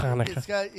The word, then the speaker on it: Hanukkah: Hanukkah. You got to find something really deep in the throat.